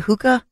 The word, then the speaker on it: hookah: According to police, it was a hookah. Am I saying that right? Hookah? Hookah, yeah. – Lounge? – Yeah, hookah lounge.